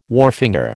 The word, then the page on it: wharfinger: American English